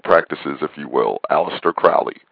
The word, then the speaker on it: Crowley: But Crowley said his name rhymed with holy.